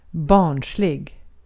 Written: barnslig

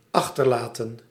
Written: jettison

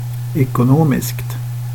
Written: ekonomiskt

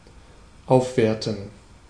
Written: appreciate

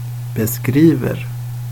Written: beskriver

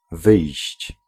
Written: ausgehen

